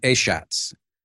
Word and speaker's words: Achatz: Grant Achatz. Achatz.